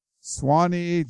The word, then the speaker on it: Suwanee: Ivan in, Suwanee, Georgia. I think that's how you say it. Suwanee, Georgia.